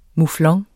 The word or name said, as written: muflon